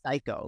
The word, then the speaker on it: Cayko: Because that's very, it's spelled C-A-Y-K-O, but it's pronounced psycho. Like, are you psycho? – Yeah, a little bit.